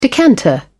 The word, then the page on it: decanter: British English